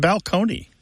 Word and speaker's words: balcony: My favorite change that I love to talk about when Martha and I go and do live events in the rows is the word balcony, you know, like this place on a stage that you can look down upon the seats, used to be pronounced balcony.